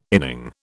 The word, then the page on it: inning: British English